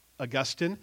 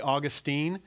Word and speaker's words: Augustine: One of them was a guy named Augustine, or you could say Augustine. It just depends on which syllable you put the emphasis.